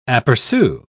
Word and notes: apercu: British English

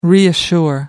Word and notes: reassure: American English

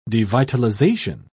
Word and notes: devitalization: British English

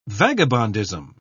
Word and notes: vagabondism: British English

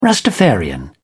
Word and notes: rastafarian: British English

